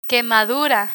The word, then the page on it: quemadura: spanish